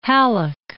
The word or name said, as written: Halleck